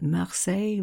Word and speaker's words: Marseille: It's no coincidence, you know, that Marseille rhymes with soleil,